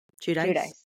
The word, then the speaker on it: Giudice: how do you say their last name? Giudice. – Yeah.